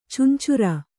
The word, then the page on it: ಚಂಚುರ: Kannada